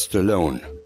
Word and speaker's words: Stallone: Ist es Stallone? – In der alten Heimat, ja. In Amerika ist es Stallone, wie Malone, mit einem stummen E.